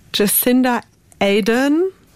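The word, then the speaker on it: Jacinda Ardern: Jacinda Aden spricht man sie aus.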